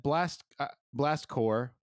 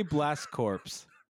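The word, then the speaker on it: Blast Corps: Anyway, Blast Core, which you don't pronounce the P or the S, Casey.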